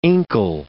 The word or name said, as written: inkle